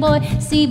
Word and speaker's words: Boise: There's lots to do in Boise, lots to see in Boise, but there is no Z in Boise. So if you're from out of town, please get this one thing down. It's really not hard to pronounce.